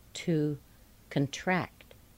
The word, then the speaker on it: contract: Notice the C-O-N is pronounced kun. Be careful with that. To concert. To contest. To contest. To contract.